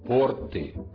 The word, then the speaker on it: порты: Запомните, мы всегда должны говорить «порты», «в портах», «о портах». – Действительно, норма произношения требует формы «порты». Неплохо было бы сразу запомнить и форму «торты».